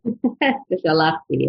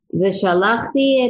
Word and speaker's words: v'shalachti: And I'm going to be persnickety and mildly correct just the syllable that you have emphasized, because in the Vav Ha'ipuch, as the Trump shows us, even though in modern Hebrew it would be v'shalachti, it's v'shalachti et yadi v'hiketi et Mitzrayim, because the Trump tells us which syllable to emphasize.